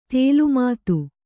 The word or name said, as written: ತಲೆಮಾತು